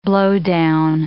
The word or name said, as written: blowdown